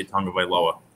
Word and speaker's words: Tagovailoa: I will show more respect, and I will say Tagovailoa. I think that that's how you say it.